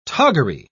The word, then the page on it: toggery: British English